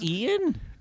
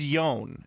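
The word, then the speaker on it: Ioan: Is it Ian? – I think it's Yon. Oh. Is how it's pronounced.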